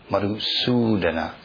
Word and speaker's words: Madhusudana: You know, so there's two U's. Madhusudana. That's like a long U.